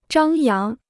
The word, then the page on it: 张扬: zhāng yáng